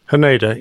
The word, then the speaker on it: Haneda: Am I saying that right? Haneda? Haneda? – Haneda, yes.